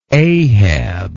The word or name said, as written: Ahab